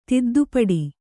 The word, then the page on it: ತಿದ್ದುಪಡಿ: tiddu paḍi